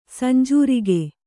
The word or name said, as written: ಸಂಜೂರಿಗೆ